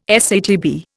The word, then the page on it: SATB: Indian